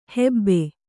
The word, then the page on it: ಹಬು: habu